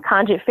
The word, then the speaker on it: Kongit: All across social media, it's just my name, Kongit Farrell.